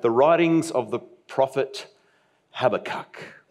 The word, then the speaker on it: Habakkuk: There are a few different pronunciations of this strange name, but we're going to go, for the sake of consistency, with Habakkuk, all right.